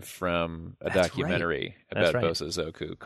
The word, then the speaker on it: Bosozoku: Oh, well, you know, speaking of things that sound like band names, I should throw into the Bozo Zuko. Man, am I saying that right? Let me just check it real quick. Bozo Zoku.